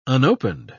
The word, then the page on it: unopened: British English